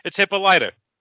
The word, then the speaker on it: Hippolyta: It's not hippa heavier. It's Hippolyta.